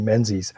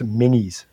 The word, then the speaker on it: Menzies: Banks, it stands for the name Menzies, or should I say what looks like the name Menzies, but is actually pronounced Minnie's.